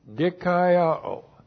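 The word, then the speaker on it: dikaiō (δικαιόω): D-I-K-A-I-O, long O, dikaiō.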